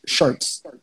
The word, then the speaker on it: Shertz: You know, from when I used to live in Crown Heights, this old Jamaican guy couldn't say my government name, which is Shertz, S-H-E-R-T-Z.